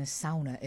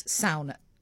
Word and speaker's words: sauna: And when he came in, he told me that you pronounce sauna, as I had been saying for years, sauna, as sauna.